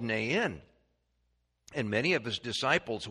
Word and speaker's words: Nain: Nain. And so if you want to pronounce it correctly, you have to make it a two-syllable word, Nain.